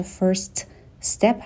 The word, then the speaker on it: first step: اینجا first step هستش و دیگه اون تی رو تلفظ نمی کنیم. دوتا اس رو به هم می چسبونیم.